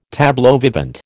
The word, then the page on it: tableau vivant: American English